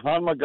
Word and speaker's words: Honma: The second thing you have here is Honma. That, that, that correct pronunciation? Honma Golf Clubs. – Yeah. Yeah, Honma golf clubs, which never go on sale, guys.